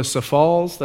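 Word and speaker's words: Sioux Falls: And then all week long, I'd visit people, go to Safalls, that's the way they pronounce it out there, Safalls, to see people in the hospital.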